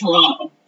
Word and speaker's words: Toronto: Yes, there's a T at the end, and so I know myself. I can tell when I'm walking down the street and if it's a foreigner or if it's a local. – Okay. – Because locals, we do not pronounce the team, so okay. – It's Toronto. – Okay. – There's no team.